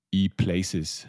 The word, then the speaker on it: ePlayces: mal den Zuhörern und mir, was ist denn genau E-Places? Habe ich das richtig ausgesprochen? – Hast du richtig ausgesprochen, sehr gut, ja.